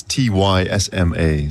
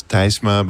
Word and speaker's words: Tysma: It's T Y S M A. So you would pronounce it in Dutch. You would pronounce Tysma, but in English it's usually Tysma.